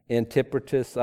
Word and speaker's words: Antipatris: I always say that wrong. Antipatris. I never know.